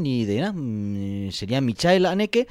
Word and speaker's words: Haneke: Haneke. Es como se pronunciaría en alemán, ¿vale? Es que solemos decir Michael Haneke.